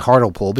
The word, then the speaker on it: Hartlepool: Pronounce the E. Okay, didn't I just do that? Hartlepool? Yeah, you see, I'm not really sure how to read this because it didn't print very well as far as pronunciation guides. They don't convert well in ASCII. But I'm going to continue saying Hartlepool because that's how it's spelled, and I think that's really the way to pronounce it.